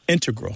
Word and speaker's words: integral: Just to clarify here, both correct, but the mathematicians and math-using scientists are more likely to put, say, integral with the stress on the first syllable.